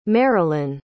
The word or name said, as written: marolyn